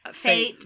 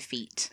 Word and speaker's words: fete: Fate versus fair. – Yeah. – It's basically a fair. – Mm-hmm. – And it's similar to affairs over here, you know. – But affairs. – It's spelled F-E-T-E. – Yeah, it's like a French loan word, I think, or something. – Yeah. So we would say feet.